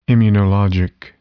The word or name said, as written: immunologic